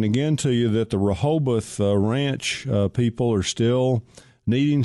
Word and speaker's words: Rehoboth: It's Rehoboth. It's R-O-H-O-B-O-T-H, Rehoboth, and it is a wonderful place.